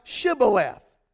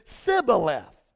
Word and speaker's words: Shibboleth: Then said they unto him, say now, Shibboleth. And he said, the Ephraimite said, Sibboleth. For he could not frame to pronounce it right.